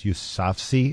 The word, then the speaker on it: Yousafzai: It's Yousafzai.